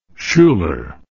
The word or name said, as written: schuller